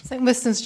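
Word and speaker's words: St Wystan's: How do we pronounce it? St Wistons? – St Wistons Church, yes.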